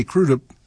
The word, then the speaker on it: Crudup: In front of Arthur Big Boy Crudup, and as you heard him say, Crudup, that is how he pronounced it. And some people say Crudup, but hey, it's his name. I guess he gets to say it how he wants.